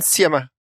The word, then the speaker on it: Sciamma: Celine Siama. It's how to Siama. – Celine Siama. It's how to pronounce it in French specifically. Yeah.